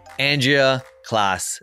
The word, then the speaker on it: Andrea Klas: I want to welcome our guest, Andrea Klas.